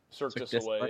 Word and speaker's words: Cirque du Soleil: Cirque du Soleil? – Cirque du Soleil, yeah.